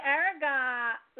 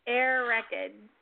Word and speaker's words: Airgead: Air record. How do you say it? – It's Celtic. It's air record. It's actually early Gaelic, but that's the most common modern pronunciation.